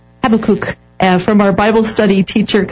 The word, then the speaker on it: Habakkuk: Oh, well, there's a couple different ways, but I'm going to go with Habakkuk.